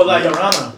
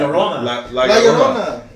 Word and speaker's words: La Llorona: No, La Llorona. – Yeah. – La Llorona.